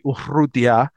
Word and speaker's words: Urrutia: Uh, David Stone, who's somebody Miami badly wants, was asked by Gabby Urrutia of uh, of Inside the U. Great pronunciation. Yes, I have been practicing.